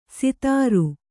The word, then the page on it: ಸಿತಾರು: sitāru